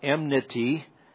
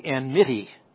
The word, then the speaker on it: enmity: Usually when you say it, it comes out enmity, but it's actually enmity.